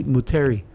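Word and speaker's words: Moutere: Um, it sounds, it seems like it's written in French, but if it's pronounced phonetically, it'd be Muteri. Uh, this beer has notes of grapefruit, lychee, lime, and passion fruit.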